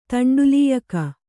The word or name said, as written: ತಂಡುಲೀಯಕ